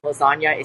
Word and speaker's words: lasagna: It's actually very, very clever because obviously the G in lasagna is silent. So there's a little bit of a double entendre there because G is not pronounced in the word lasagna.